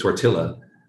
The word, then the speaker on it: tortilla: with a name called tortilla, or as they pronounce it, tortilla, it was hard going.